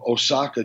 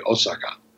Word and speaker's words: Osaka: So it's usually like in English, people say, oh, you're going to Osaka, Japan. And in Japanese, it would be Osaka. So there's no emphasis on syllables.